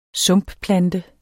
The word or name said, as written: sumpplante